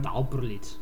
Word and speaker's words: Tauperlitz: Bueno, la mujer de la que vamos a hablar es Thea von Harbou, una alemana nacida en Tauperlitz. Corrígeme si me equivoco en la pronunciación. – Tauperlitz. – Vale.